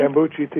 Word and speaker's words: kombucha: Kombucha tea, yeah.